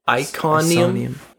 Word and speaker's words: Iconium: I-C-O-N-I-U-M. Iconium? Iconium? Iconium? – Smithsonian.